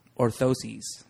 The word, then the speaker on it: orthoses: So correct me if I'm wrong. Orthoses. Did I say it right? – Yes, she did.